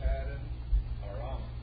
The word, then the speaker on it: Paddan Aram: Arise, go to Paddan Aram.